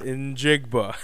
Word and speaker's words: Njigba: Njigba.